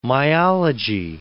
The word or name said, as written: myology